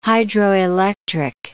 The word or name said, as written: hydroelectric